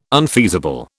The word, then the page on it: unfeasible: British English